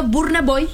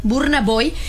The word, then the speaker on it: Burna Boy: se pronuncia así, güerito, Burna Boy. – Tal cual, sí, Burna Boy.